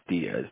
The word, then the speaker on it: Diaz: E-V-A-N. My last name is Diaz, D-I-A-Z.